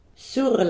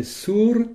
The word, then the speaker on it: sur: Alors attention de bien prononcer sur. – Sur la fenestro. – Et pas sur comme en français.